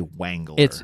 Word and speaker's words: Wengler: IMDb says Wrangler, but no, they say Wengler. – They pronounce that W so hard.